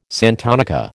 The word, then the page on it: santonica: American English